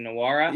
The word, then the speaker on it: Nwora: Jordan Nwora. I believe I'm pronouncing that correctly, Nwora.